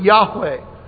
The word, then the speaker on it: Yahweh: We've tried to give it a pronunciation, so we pronounce it Yahweh.